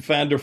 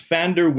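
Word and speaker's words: Pfander: Yes, you can come to Fander Films. It's spelled P-F-A-N-D-E-R. F-I-L-M-S.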